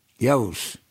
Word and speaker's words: Yoav: Y-O-A-V. How would you pronounce that? – Yoav.